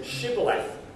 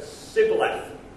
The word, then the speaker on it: Shibboleth: He made everyone say the word, who was coming back in, Shibboleth, because the Ephraimites couldn't say shh. They couldn't pronounce shh. So they said, instead of saying Shibboleth, they said Sibboleth.